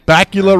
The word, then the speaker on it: Bakula: Bakula nice. Rhymes with Dracula.